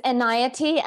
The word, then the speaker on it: Enayati: It's Enayati. And somebody just pointed out to me that it sounds like anxiety.